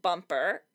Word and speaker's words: Bumpr: Well, this week I'm feeling an app for Mac called Bumper. It's spelled B-U-M-P-R.